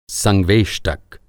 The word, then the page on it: संवेष्टक: Hindi